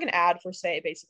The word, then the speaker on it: Saie: I love Say Beauty. It's spelled S-A-I-E.